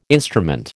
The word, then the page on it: instrument: American English